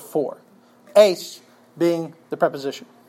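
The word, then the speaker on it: eis (εἰς): It is a preposition, E-I-S in the Greek, "eis" is how it would be pronounced, and the preposition has up to 8 different nuances of meaning.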